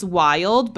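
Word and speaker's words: VYLD: So we have a company called, it's pronounced Wild, but it's V-Y-L-D.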